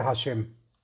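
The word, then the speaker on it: השם: deberá meditar el santo nombre de Hei Mem, que se pronuncia Hashem.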